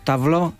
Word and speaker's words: tableau: En francés, por ejemplo, sí, cuando decimos tableau, termina en E-A-U y se pronuncia O.